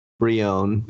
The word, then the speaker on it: Brionne: No, it's B-R-I. Like Brie. – Brioni. – Brionne. Because I think there's probably, I don't know any French, but I assume there's some sort of French thing happening there, which is why you wouldn't pronounce, which is what I think the N-N-E is kind of the giveaway of the French pronunciation. Yes. So like Brionne.